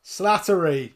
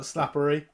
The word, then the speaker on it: Slattery: Slattery, not Slappery. Slattery, that's it. – Slappery. – Yeah. Slattery.